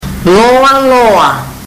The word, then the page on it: loaloa: fiji